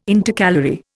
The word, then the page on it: Intercalary: Indian